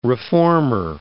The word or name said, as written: reformer